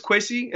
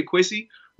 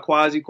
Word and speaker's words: Kwasi: The whole season, I, like my name is Quissy in the African dialect. You say Quissy. But most people can't pronounce it, so we always go by Kwasi, Kwasi, Kwasi. But it's Quissy.